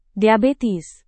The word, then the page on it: diabetes: Indonesian